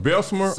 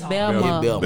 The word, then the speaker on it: Belmar: You from Belsmer or Belsmer? – Nah, he said Belmar. – Belmar. – Belmar. – Ain't no S's. It's just Belmar. – Spell it. B-E. – L-M-A.